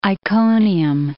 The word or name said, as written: Iconium